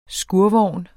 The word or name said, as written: skurvogn